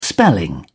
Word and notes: spelling: British English

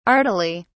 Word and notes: artily: British English

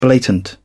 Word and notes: blatant: British English

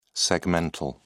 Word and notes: segmental: British English

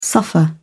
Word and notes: suffer: British English

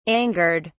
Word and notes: angered: British English

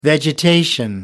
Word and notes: vegetation: American English

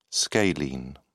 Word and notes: scalene: British English

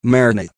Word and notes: marinate: American English

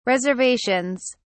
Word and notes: reservations: British English

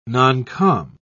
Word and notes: noncom: British English

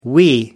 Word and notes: wee: American English

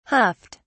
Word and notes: huffed: British English

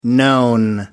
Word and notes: known: American English

